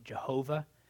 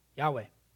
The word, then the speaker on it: Yahweh: Sometimes some people pronounce it Jehovah, which actually comes from a German strain of thought. So I think Yahweh is accurate.